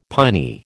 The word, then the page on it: piny: American English